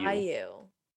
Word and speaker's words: Daiyu: D-A-I-Y-U. So Dayu. – Dayu.